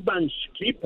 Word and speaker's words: Van Schip: John Van Schip, así como este, como pronunciamos los holandeses.